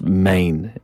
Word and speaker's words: Men: uh, and despite the fact it's called Enys Men on the title, uh, it, it, Men is pronounced Main in yeah.